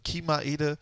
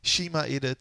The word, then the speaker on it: Chima Ede: Chima Ede, Chima Ede, C-H-I-M-A Ede das Wort, äh, das Lied Geh.